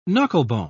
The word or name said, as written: knucklebone